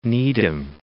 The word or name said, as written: Needham